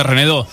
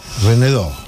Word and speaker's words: Renaudot: ¿Cómo se pronuncia eso? – Sí, es medio francés, Renaudot. – Renaudot.